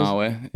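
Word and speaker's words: Banaue: Riding down the fields at, and I don't know if I'm going to say this right, Banau, the rice terraces. – Oh, Banawe, yeah.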